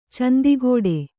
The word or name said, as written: ಚಂದಿಗೋಡೆ